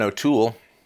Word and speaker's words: O'Toole: And now we've got Erin O'Toole, emphasis on the last syllable, conservative leader in Canada going up against Justin Trudeau, and he's saying, oh, we got to get north of 90%.